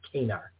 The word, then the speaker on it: Kanar: I need to get on this train because I was corrected and I looked back and it is the correct pronunciation. It's Kanar. So sorry about that.